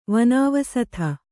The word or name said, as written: ವನಾವಸಥ